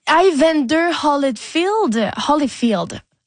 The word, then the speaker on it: Evander Holyfield: Evander Holyfield.